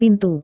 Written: pintu

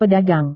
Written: pedagang

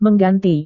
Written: mengganti

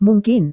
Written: mungkin

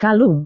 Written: kalung

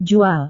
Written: jual

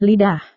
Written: lidah

